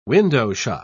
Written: window-shop

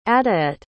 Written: adoette

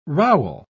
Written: rowel

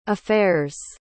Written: affairs

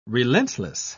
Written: relentless

